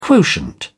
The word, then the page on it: quotient: British English